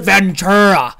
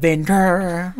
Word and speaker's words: Ventura: You pronounce it Ventura. That's what I said, right? I said Ventura.